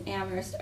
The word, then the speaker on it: Amherst: We learned that the town folk in Amherst are so vocal. – Mm-hmm. – They say nothing but the H is silent. – So aww. The H in Amherst, it's actually Amherst.